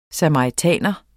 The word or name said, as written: SAMARITAN